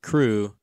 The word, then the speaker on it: CHRU: The Carolina Harm Reduction Union, known as CHRU, pronounced CRU, is a student-led program that provides life-saving harm reduction resources to the public of Chapel Hill.